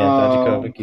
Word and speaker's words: Dijakovic: Dijakovic, yeah. Yeah, Dijakovic, you said it right that time.